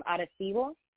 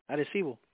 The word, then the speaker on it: Arecibo: So I know for a fact that my maternal side is from like my paternal grandmother is from Arecibo. I'm not saying it right. – Arecibo.